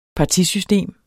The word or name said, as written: partisystem